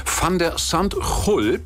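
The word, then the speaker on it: Van de Zandschulp: Van der Sandhul, so wird er genau ausgesprochen, ist heute Nacht unserer Zeit Gegner von Alexander Zverev bei den US Open in New York im Viertelfinale.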